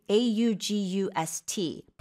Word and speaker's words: august: It's spelled the same. A U G U S T. 8월이랑 같은 스펠링인데 yeah. – 의미가 다르죠. – Yeah, the stress is on the second syllable mm-hmm.